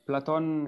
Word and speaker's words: Platon: Platon is actually how his name is pronounced and spelled, Platon.